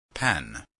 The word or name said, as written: pen